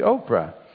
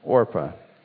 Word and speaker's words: Orpah: And you might say, well, that's spelled wrong. It should be Oprah. Well, maybe those two names are related, I don't know. But this woman's name was Orpah, and the other was Ruth.